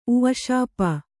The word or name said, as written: ಉಃಶಾಪ